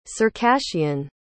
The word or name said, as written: circassian